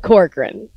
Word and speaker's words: Corcoran: Jen Corcoran.